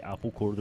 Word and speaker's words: Abegor: And I think the fact that Abegor, you don't pronounce the L, right? It was a thing about how we actually pronounce his name, and it is closer to Abegor than we thought.